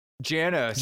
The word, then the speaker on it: Giannis: Kungu and Giannis, those are Giannis, right? Just came back. – Janos.